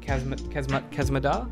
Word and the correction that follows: Maz Kanata: Maz Kanata. – Maz Kanata.